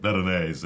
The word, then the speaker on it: Veronese: That's how he pronounced it, Veronese." – "That's the correct pronunciation."